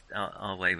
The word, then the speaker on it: Ave: Because Our Way was the Latin greeting. Even though it looks like Ave, they pronounce it.